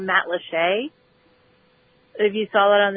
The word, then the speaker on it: Matlacha: Every day when I left my house, I had to drive through Matlacheux. It's spelled Matlacha, but It's pronounced Matlacheux. Anyway, so that area was, like, destroyed.